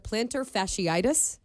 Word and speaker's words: plantar fasciitis: Of course, he suffered plantar fasciitis.